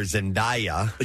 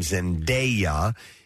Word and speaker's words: Zendaya: For the longest time, I called her Zendaya. – But you were wrong. – Until I heard herself pronounce the name Zendaya, is how you do that.